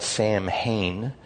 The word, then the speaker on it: Samhain: And what the church did was adopt both the time Saturnalia is what it was called for Christmas, and Samhain, it's spelled Samhain, but it's pronounced Samhain, where we get Halloween, and then it went to the Celts.